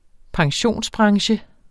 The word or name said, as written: pensionsbranche